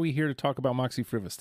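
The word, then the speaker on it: Moxy Früvous: Is it Moxie Fruvis? Did I say it correctly? – You did. You did excellently. Thank you. – There we go. – It is Moxie Fruvis.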